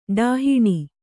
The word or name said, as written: ಡಾಹಿಣಿ